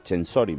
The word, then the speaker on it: Censori: De todas formas, oye, muy comentados los looks, entre ellos el de Blanca Censori, me he enterado que, sí, se dice así el apellido, que es la mujer, pues, de Kanye, mejor, West.